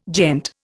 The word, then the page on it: gent: Indian